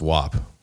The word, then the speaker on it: WAP: And Cardi B apparently has done that, where, you know, that famous song WAP that she came out with, WAP, it's WAP for the record. That's how you pronounce it.